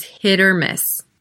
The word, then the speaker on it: hit or miss: It's a flap T that sounds like a D. It's quite quick. Once again, I said it was hit or miss. Because the stress is on hit, you could almost barely hear that T running into the O that follows.